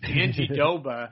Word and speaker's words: Jandiroba: Amanda Ribas, the cutest little fighter in the UFC, versus Verna Jandiroba. Or I guess it's Jandahoba, right? – If you ask the UFC announcers, it's Jandiroba. – Oh, that's right. Yes, they put the D in there, Jandiroba.